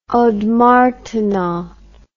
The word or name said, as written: ondes martenot